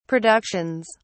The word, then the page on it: productions: British English